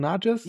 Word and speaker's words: Nagis: hält mir, wie wird sie ausgesprochen, Nagis? – Nagis.